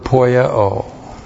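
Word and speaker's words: Poieo (ποιέω): Start out with the verb poieo. P-O-I-E long O. Poieo.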